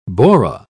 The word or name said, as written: bora